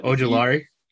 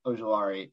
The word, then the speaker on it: Ojulari: But yeah, Aziz, I can never pronounce his last name, but Ojulari. – Ojulari.